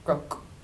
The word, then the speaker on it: Groke: The Groak is described, and this for people who are listening, G-R-O-K-E. That is how it's spelled. – Groak. – Groak. – Very good.